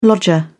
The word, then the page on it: lodger: British English